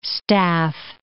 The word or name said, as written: staff